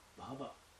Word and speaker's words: bhava: Short. Yeah. Short A. Bhava. I'm doing it long there? – Yeah. – Bhava. – Bhava. – Bhava. – Yeah. – Okay. I remember the correction from last year. I actually want to make that point. Because bhava with long A, bhava, refers to ecstatic emotion, and non-ecstatic emotion will be shorter than that.